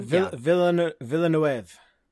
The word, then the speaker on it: Villeneuve: And if you guys have seen the movie Enemy with Jake Gyllenhaal, directed by Villeneuve. Is that how you pronounce his name? – Villeneuve.